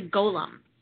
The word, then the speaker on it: golem: So it's a golem. It's pronounced golem. It's golem.